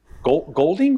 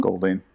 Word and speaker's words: Golding: Golding? – Golding. Yeah, Golding. Silent U. Funny U, but it's silent.